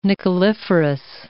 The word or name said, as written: nickeliferous